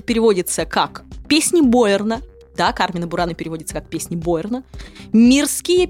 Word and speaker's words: Carmina Burana: Так вот, правильно произносить следует Кармина Бурана, а не Кармина Бурана, как это делают сегодня очень многие.